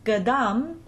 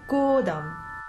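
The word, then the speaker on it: Godam (годам): It's not Gadam, as they have it transcribed, but Godam.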